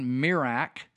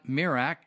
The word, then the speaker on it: Zmirak: I want to make some comments about an article written by a guy named John Mirak. It's spelled Z-M-I-R-A-K. John Mirak, and he says, watching Tony Bobulinski on Tucker Carlson last night drove me to a thought experiment.